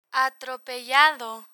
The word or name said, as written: atropellado